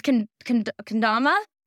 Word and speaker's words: Kendama: And I always pronounce it wrong. It's Kendama.